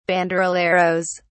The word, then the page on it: banderilleros: British English